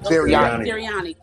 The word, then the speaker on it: Sirianni: Sirianni.